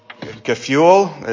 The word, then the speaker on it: Gefühl: But then his emphasis, and this is very subtle, was something called Gefühl, is how you say it in German. But it was this idea of like ardent feelings or desire evoked from exposure to God's word, evoked by theological concepts.